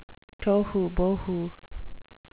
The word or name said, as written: tohubohu